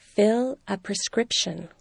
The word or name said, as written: fill a prescription